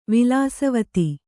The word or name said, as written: ವಿಲಾಸವತಿ